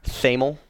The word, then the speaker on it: Thamel: He's Tamil? One. – TH. – Thomas is a TH. Tamil? – No, it's Thamel is how Thamel.